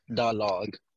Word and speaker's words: Dalog: Da log? Because I, you know, log. I... The log. Da log. – Da log.